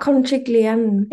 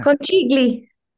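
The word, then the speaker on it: conchiglie: Oh, it's not conchigliani. – It is. – Conchigliani. – Can you see that? – Yeah. – Conchigli. There you go.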